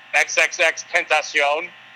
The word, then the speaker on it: XXXTentacion: And then not to speak ill of the dead, but I believe I'm pronouncing this right, but XXXTentacion. – Yes.